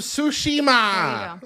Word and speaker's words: Tsushima: Ghost of Tsushima.